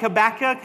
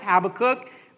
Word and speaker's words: Habakkuk: Just like Habakkuk, Habakkuk, one and the same, all right?